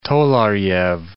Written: tolarjev

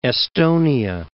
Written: Esthonia

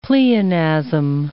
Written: pleonasm

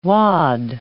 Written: wad

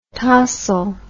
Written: tossle

